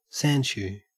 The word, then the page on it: sandshoe: Australian English